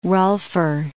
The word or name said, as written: rolfer